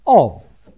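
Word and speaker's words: of (אוב): The group of demons have the title OB, which is pronounced in Hebrew, of.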